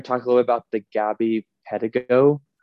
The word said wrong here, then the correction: Petito: I don't know how to say her last name. One of that case that's going on. – That's what I thought. – Petito, thank you.